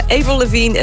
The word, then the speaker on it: Avril Lavigne: It's a damn cold night, trying to Avril Lavigne, ja, zo zou je het waarschijnlijk officieel moeten uitspreken, want haar vader is Frans en ze is vernoemd naar de maand april, maar dan wel de Franse variant daarvan.